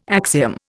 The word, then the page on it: axiom: Indian